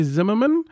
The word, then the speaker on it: Zimmerman: This is Bailey Zimmerman. I actually said it correct. If you're listening to the show, I can never, ever pronounce Bailey's surname correctly on the first go, but I got it.